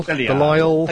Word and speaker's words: Belial: Belial. – I think they pronounce it Belial, yeah.